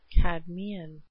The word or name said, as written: cadmean victory